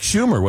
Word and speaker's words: Schumer: I believe that was Chuck Schumer, was it not? – Yes. Yes. The senator's name rhymes with rumor.